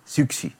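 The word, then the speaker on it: Siouxsie: Ε, το πες πολύ, το, το Έχει λίγο προφορά, είναι Suzy, είναι, έχει X. – Τι κάνει! – Έχει X και Silent S. Suzy, Σιούξι.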